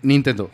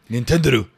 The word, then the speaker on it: Nintendo: Así se dice. ¿eh? Nintendo. – Sí, así. Nintendo.